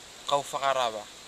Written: kaufaghawara

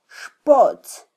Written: xpotz

